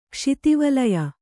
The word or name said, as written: ಕ್ಷಿತಿವಲಯ